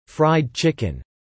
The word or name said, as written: fried chicken